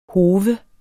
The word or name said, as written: hove